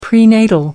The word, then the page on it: prenatal: American English